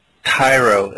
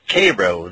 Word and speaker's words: Cairo: most people would think is called Cairo, Illinois, just of how it's spelled, but it's Cairo there.